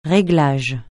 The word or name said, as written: réglage